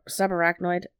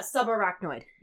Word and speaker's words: subarachnoid: The head and skull showed multiple abrasions, fractures, and subpar acrid hemorrhage. Did I say that right? – I have to see what you're writing. Well, sub subarachnoid. – Yeah, subarachnoid.